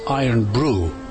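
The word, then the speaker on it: Irn-Bru: Anyway, I bought a bottle of the stuff, only to find out that it's pronounced Irnbru, and it is Scotland's number one soft drink.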